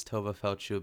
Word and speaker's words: Tova Feldshuh: I came across that episode where they realized they were pronouncing it wrong, because they've been saying Tova Feldshuh, mm-hmm. Feldshuh, which it sounds wrong, but it feels so right at the same time.